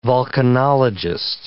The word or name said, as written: vulcanologist